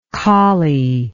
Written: collielike

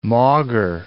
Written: maugre

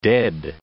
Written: dead

